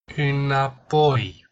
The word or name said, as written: inapoi